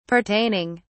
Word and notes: pertaining: British English

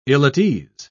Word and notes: ill-at-ease: British English

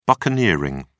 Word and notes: buccaneering: British English

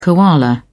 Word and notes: koala: British English

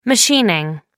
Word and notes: machining: American English

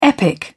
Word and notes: epic: British English

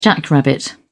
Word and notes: jackrabbit: British English

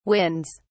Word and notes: winds: British English

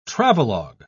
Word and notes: travelog: British English

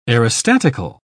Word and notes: aerostatical: British English

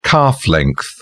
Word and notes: calf length: British English

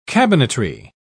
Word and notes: cabinetry: British English

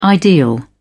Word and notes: ideal: British English

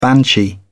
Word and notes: banshee: British English

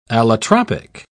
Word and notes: allotropic: British English

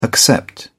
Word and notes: accept: British English

